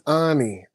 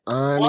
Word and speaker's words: Awny: It is Awny. Awny.